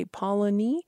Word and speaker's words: Polony: You pronounce Polony. It's three syllables.